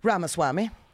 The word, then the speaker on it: Ramaswamy: Ramaswamy Ramaswamy.